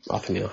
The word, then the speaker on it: Othniel: Othniel. Othniel, how you pronounce the name.